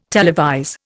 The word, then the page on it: televise: Indian